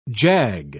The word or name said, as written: jag